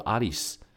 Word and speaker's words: 有栖: 所以有栖的发音叫做 Alice，就是我们《爱丽丝梦游仙境》的这个爱丽丝。